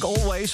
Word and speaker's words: Alvvays: Ze heten namelijk Always, of zo spreek je het uit. Maar je schrijft het met twee V's in plaats van met een W. Dus het is A-L-V-V-A-Y-S.